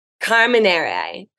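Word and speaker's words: Carmenère: It's called Carmenye. Carmenere, Carmenere, which is sort of a blend.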